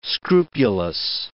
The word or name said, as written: scrupulous